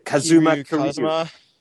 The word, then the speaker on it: Kazuma Kiryu: Kazuma Kiryu.